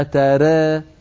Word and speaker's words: ترى: قالت: ما ترى في خلق الرحمن من تفاوت، فارجع البصر هل ترى، جاءت كلمة ترى مرتين، الأولى نطقتها هكذا ما ترى، هذا النطق صحيح لأن التقليل وهو الإمالة الصغرى للراء والألف التي بعدها، لكن التاء مفتوحة